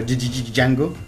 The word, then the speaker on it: Django: Que la no se pronuncia, pero Django.